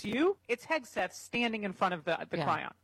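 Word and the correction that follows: chyron: Isn't it Chiron? Chiron. I never pronounce everything right. But Chiron. – Chiron is correct. It's Chiron.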